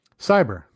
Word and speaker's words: CY8ER: Cyber. – It's just Cyber? – C-Y-8-E-R, but it's Cyber. Yeah. – Okay. That's what I was confused. If it was supposed to be Cyber.